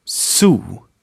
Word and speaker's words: zoo: It is pronounced just like a normal unvoiced S. Here are a few examples. Zenit. Zenit. Zoo. Zoo. Not zoo, but zoo.